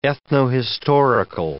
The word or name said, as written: ethnohistorical